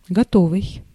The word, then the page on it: готовый: [ɡɐˈtovɨj]